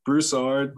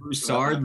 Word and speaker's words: Broussard: Did I pronounce your city right? Oh my gosh. – Broussard. – Broussard, Louisiana. – Broussard, Louisiana. – When I said it, I was like, you know, I'm pretty sure I said that incorrectly there, but awesome.